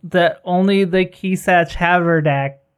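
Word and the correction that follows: Kwisatz Haderach: can use. I can't remember how you pronounce that. I probably butchered it so bad. – Oh. – Haderach. Kisatch Haderach. – Kisatch Haderach.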